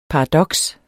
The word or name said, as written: paradoks